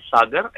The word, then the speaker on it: Sagar: My name is Sagar, S-A-G-A-R.